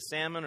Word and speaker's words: Salmon: It is not pronounced like the fish. It's actually pronounced more like the bacterial infection. Salmonella, like salmon.